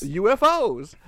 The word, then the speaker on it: UFOs: UFOs. Yeah, you put the accent on the O.